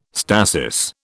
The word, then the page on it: stasis: British English